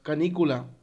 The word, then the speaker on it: canícula: porque es, no, es canícula, porque es K con N, código con acento en la I.